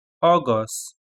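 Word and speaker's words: Ogos: أغسطس تختلف قليلا عن اللغة الإنجليزية، ركز على النطق أغسطس بدون أن تنطق تي في النهاية. أغسطس أغسطس أغسطس.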